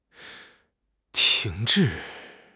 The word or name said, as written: 停滞